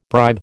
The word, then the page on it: bribe: American English